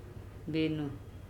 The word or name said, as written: beno